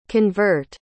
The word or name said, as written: convert